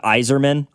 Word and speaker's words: Yzerman: I knew I was going to mispronounce a name. Somebody says it's Steve Yzerman.